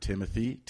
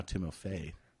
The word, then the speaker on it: Timofey: And, you know, I've heard Timothy, but now Timofey.